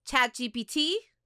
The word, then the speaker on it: ChatGPT: And actually, we were like, let's use ChatGPT. Did I say it right? – Yeah. – Or was it GPI? – GPT.